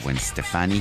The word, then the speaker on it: Stefani: Hollaback Girl, Gwen Stefani, que es la pronunciación correcta, Gwen Stefani.